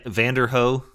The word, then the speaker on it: Vanderohe: So yeah, Van- Vanderohe or yeah, Vanderohe, right? That's that's it's spelled V A N D E R O H E.